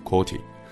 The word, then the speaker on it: QWERTY: 这组 QWERTY 的字母正是我们现在最常用的键盘的开头的几个字母。实际上啊，这几个字母拼在了一起，中文的读音是阔提，英文的读音应该是 QWERTY，所以啊，我们现在用的键盘就被称作为阔提键盘。